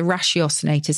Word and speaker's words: ratiocinator: If there's one word that sums him up, it's a ratiocinator. So it's spelled R-A-T-I-O, like ratio, and then cinator, C-I-N-A-T-O-R.